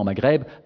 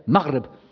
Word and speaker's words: Maghreb: Sharq, l'endroit dans lequel le soleil se lève, et l'autre, qu'on prononce bêtement Maghreb, Marb, c'est-à-dire endroit dans lequel Rarb.